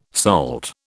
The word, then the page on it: SALT: British English